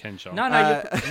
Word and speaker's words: Ken Jeong: Yeah, in Community as well, there's an episode where Ken Jeong, pronouncing that weird. – Yeah, Ken Jeong. – No, you're nailing it.